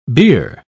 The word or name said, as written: bier